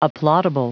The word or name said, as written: applaudable